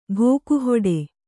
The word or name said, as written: ಘೋಕುಹೊಡೆ